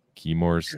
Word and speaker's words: Chemours: but instead we got Chemours. Is that how you say it? Chemours? – Yes, Chemours. It is.